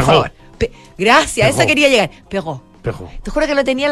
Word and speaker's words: Perrault: Perrault es como atrás. – Al final. – Es una cosa importante para el. – Charles Perrault. – que uno realmente no lo toma tan en cuenta. – Es cierto. – En la pronunciación de, o el intento de pronunciación de otros idiomas, que es donde tiene que resonar la letra.